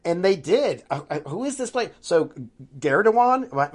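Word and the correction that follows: Gündoğan: am I pronouncing his name? – Gundawan. – Gundawan.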